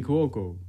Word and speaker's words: Cuoco: Quintina Bronson, Abbott Elementary, Kaylee Kuoko? – Kaylee Cuoco. – Cuoco? – Yeah.